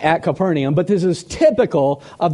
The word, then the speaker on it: Capernaum: We're at Capernaum, or Capernaum is how it would be pronounced, and it means village of comfort.